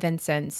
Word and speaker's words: Vincennes: So we think we're pretty confident it's Vincennes, but if you Google it, you will get four different pronunciations. So we went to the source, to the people, and they said Vincennes.